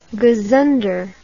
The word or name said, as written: gazunder